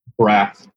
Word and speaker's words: Braff: Braff.